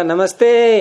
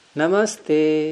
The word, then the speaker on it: नमस्ते: अगर हम नमस्ते बोलेंगे और आपने कहा नमस्ते, ये गलत है, नमस्ते ते को अनुदात,